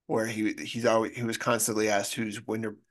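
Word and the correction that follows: Widmerpool: I can't say his name, Widmer Pool. – Widmer Poole.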